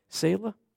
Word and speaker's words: Selah: is it Selah or Selah? Selah? I always say it wrong, then. It's a good thing I ask. So there's a number of places where it says Selah.